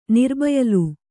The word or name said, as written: ನಿರಬಯಲು